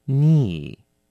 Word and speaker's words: knee: 小心哦要念成 arm 那个 r 拍配上 m 要卷个舌哦是 arm 第二个字 ear 名词耳朵 OK ear E-A-R 念成 ear 小心要轻一点短一点第三个字 finger 名词手指第四个 knee 名词膝盖小心咯在拼字的时候 knee 这个单字是 K-N-E-E 好它的 K 是不发音的所以呢念的时候只念成 knee 但是拼的时候是要把 K 要拼出来的就像 no Do you know?